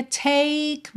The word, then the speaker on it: take: O E de take não vai ser pronunciado, então fica take me, me.